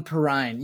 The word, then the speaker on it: Perrine: John Perrine rhymes with wine.